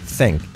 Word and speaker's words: think: Cuidado la pronunciación. CC. Una Z fuerte con la lengua entre los dientes. Think. Y la I como una E. Think. No de think. Think. Think. Y la K al final. Think.